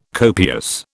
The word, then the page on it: copious: British English